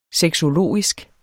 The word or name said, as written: sexologisk